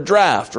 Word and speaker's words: draught: And when he had left speaking, he said unto Simon, Launch out into the deep and let down your nets for a draught," or a draught, however you want to pronounce that.